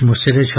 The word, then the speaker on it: مسهر: و اگر اشخاص دیگری مثل قیس ابن مسهر، البته صحیحش مسهر، حالا توضیح